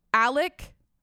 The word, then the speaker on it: Alex: Let's get the pronunciation right, first of all. It's Alec Murda that's how everyone says his name, but it's spelled A L E X.